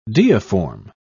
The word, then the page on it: deiform: British English